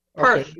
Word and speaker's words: Perth: Perth. Not Perth. It's like purse, but with a lisp. Perth.